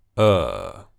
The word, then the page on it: ɜː: long vowel sound